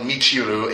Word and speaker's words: Michiru: because like taking on a role of Michiru, aka Sailor Neptune.